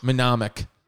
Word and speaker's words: mnemonic: How do you say that word? M-N-E-M-O-N-I-C. Is it mnemonic? Mnemonic. Mnemonic. Mnemonic. – Mnemonic. – Mnemonic. Yeah, mnemonic possession. Stop is the things that you have to remember. S-T-O-P.